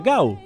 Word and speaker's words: Gal: Entonces él dijo, me gusta más Gal, con L, que también en casi todo Brasil se pronuncia Gau, con U.